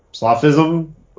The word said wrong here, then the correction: solipsism: Anyways, solipsism.